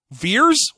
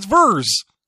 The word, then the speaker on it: Vers: What you mostly learn from when you see the dog tag is that Kree pronounce single E's in the beginning of words as sharp E's, like assholes. Veers? It's Vers. – Yeah. – It's clearly Vers.